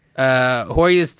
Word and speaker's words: Hoyas: Hoyas? – Yeah. – Hoyas team mascot.